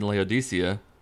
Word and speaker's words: Laodicea: To the angel of the church in Laodicea, yeah, that's right, I looked up the pronunciation, write this.